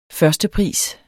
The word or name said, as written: førstepris